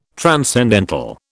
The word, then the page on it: transcendental: British English